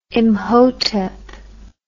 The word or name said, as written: Imhotep